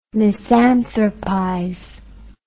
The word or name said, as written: misanthropize